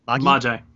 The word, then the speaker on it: Magi: Yeah, it's actually pronounced Magi. So. – Are you sure? – I am. I checked the kana. It is Magi. – Okay. – I thought it was Magi too, but no, it's Magi. So, yeah, it's a Japanese pronunciation.